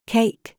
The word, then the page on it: FACE: British English